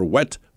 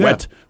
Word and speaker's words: Whet: His name, and this is not a lie, by the way, his first name is spelled W-H-E-T, Dr. Wet Farts. Farts, F-A-A-R-T-Z. He's a real person. He's been interviewed many times. – W-H-E-T, first name? – W-H-E-T, Dr. Yeah. Wet Fart.